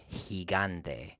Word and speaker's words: gigante: So we have words like gemelo, G-E-M-E-L-O, gemelo, which means twin. And we have words like gigante, which is spelled G-I-gigante. Gigante is actually an interesting word for this because we have he, G-I, gan, G-A-N-T-E, gigante.